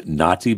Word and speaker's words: Nauti: That's Naughty by Nature, Nature Boy. And Naughty is spelled N-A-U-T-I as in nautical. Get it right. N-A-U-T-I as in nautical.